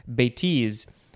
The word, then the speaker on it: bêtise: I think maybe I said bêtise before, but the emphasis is on the second syllable, bêtise.